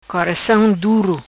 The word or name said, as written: Coracam Duro